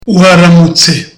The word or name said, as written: waramutse